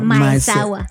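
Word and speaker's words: Maezawa: Pues hay un multimillonario japonés que se llama Yasuku Maeseawa, o lo estoy pronunciando Maesawa.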